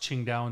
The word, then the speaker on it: Qingdao: got to the city of Qingdao, which is where they make that Chinese beer, the green bottle called Tsingtao. – Yeah. It's pronounced Qingdao in Chinese.